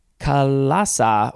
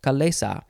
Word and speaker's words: chalaza: We are moving on to Kalasa or Kalesa. It is spelled C-H-A-L-A-Z-A. Kalesa. We'll say that. Kalesa.